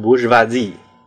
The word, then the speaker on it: bourgeoisie: 而城市人 burger 这个词儿在法文里呢，拼成 b o u r g e o i s i s，读法呢大概是读成 bourgeoisie，翻译成中文呢就叫做布尔乔亚。